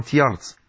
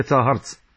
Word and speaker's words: تاهرت: وعاصمتها تاهرت، تنطق اليوم تيارت لكن هي تاهرت، وبقيت هذه الدولة لمدة وهم يتوالون على ملكها.